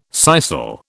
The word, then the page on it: sisal: British English